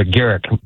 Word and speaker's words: Garrick: That's a Garrick with a hard G.